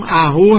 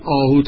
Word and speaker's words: اهو: و اینجا هم اهو هست که حالا بعد آهو تلفظ میکنیم